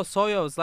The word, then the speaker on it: Soyuz: Soyuz. Oh, Soyuz, like the Soyuz, like the spacecraft Soyuz.